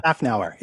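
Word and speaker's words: Szafnauer: And that is, I'm sure I said that right, Otmar Schaffnauer. – Schaffnauer, exactly.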